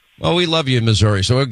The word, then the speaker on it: Missouri: Let's say hi to Jack is in Missouri. Now, some people say Missouri, some people say Missouri. Anyway, well, how do you say it, Jack? – Missouri.